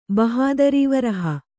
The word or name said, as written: ಬಹದರಿವರಹ